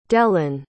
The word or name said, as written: dellyn